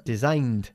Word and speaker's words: designed: Porque design no acaba ni en T, ni en D, ni en un sonido de T, ni en un sonido de D, ¿eh? Designed, por lo cual no pronunciamos ed, is designed.